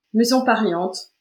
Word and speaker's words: Maison Pariente: Maison Pariente, ouais, on dit ça comme ça. Certains mettent un petit accent, mais en disant Maison Pariente, ça fonctionne très bien.